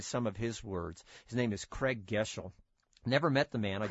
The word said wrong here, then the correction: Groeschel: Craig Groeschel.